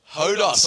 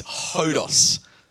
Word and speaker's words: hodos: The correct pronunciation of this word is hodos. Hodos. So why don't you turn to the person next to you and say, hodos.